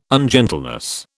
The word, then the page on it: ungentleness: British English